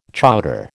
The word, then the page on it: chowder: American English